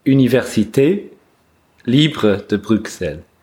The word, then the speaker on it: Université Libre de Bruxelles: at the Universitat Libre de Brussels. – Okay. – How do you pronounce that? – Université Libre de Bruxelles. – Okay, that's much better.